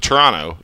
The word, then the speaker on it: Toronto: But, like, no one in this city calls it – it's same thing like with Toronto. Like, Toronto, you don't pronounce the T at the end unless you're from out of Toronto.